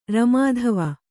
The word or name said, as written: ರಮಾಧವ